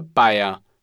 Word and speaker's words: Beier: Sie heißt Sprachschule Bayer. Das schreibt man B E I E R.